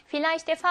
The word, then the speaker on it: Vielleicht: 자, 그러면 Vielleicht의 V는 F 발음을 냅니다.